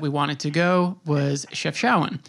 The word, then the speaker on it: Chefchaouen: Uh, is Chef Showen correct? – Yes, Chef Showen. Chef yes, Showen. Very. Yep.